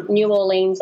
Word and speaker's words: New Orleans: New Orleans, of course. Or I'm saying that wrong. But no, you're not.